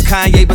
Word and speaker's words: Kanye: Now all I need is y'all to pronounce my name. It's Kanye, but some of my plans, they still say Kane.